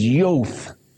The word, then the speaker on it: Jod: Now, in the 73rd verse, Look above that, you see the English word J-O-D. And if I could pronounce that correctly, that is Yoth.